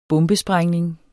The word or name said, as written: bombesprængning